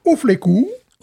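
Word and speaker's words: U Fleků: Alors, la plus vieille brasserie tchèque, attention, Ouflekou. Voilà. Le tchèque, en fait, ça s'écrit bizarre, mais ça se prononce assez bien, finalement.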